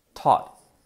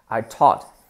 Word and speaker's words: taught: Lo mismo, la pronunciación. En ambos es este sonido, ¿vale? No es ni una O, I taught, ni una A, I taught. Es justo una mezcla entre las dos.